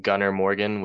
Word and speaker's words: Gunnar Morgan: My Instagram is just first name, last name, Gunnar Morgan with two N's.